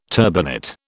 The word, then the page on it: turbinate: British English